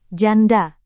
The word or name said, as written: janda